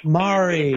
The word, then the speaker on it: Mari: Mari.